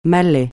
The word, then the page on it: Malais: francais